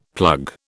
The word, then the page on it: plug: British English